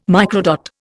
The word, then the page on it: microdot: Indian